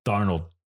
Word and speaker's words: Darnold: Darnold.